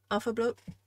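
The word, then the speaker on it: álfablót: Sharon's cat's asked, do you perform alpha blood? Said that right? – Yep, alpha blood.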